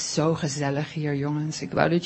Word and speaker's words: gezellig: How do you say that? – Gezellig. – Gezella? – It's almost like flippin' floppin'.